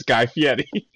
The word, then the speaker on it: Fieri: There are two names where I feel extremely douchey when I pronounce them correctly, and that's Hayden Panettiere, and it's Guy Fieri. and I know those are the correct pronunciations because they've said them.